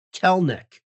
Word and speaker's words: Kelenic: Yeah, maybe I've heard him say Kelenic. – Kelenic? – Yeah. – Here's a story from Seattle Times in 2019, March 14, 2018. And they put in parentheses pronounced Kelnick.